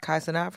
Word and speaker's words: Kai Cenat: Number 6 was Kai Cenat. Did I say that right? – Kai Cenat from Twitch.